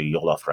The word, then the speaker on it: jollof: Jambalaya is very similar to jollof rice. Is it jollof or jollof rice? How do you know how to pronounce it correctly? – Jollof. – Jollof, okay.